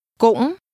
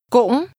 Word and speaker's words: cũng: Cũng. – Which part of that do you think means also or too? – Cũng. – This word is pronounced with a high, rising, broken tone.